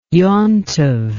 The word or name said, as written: yomtov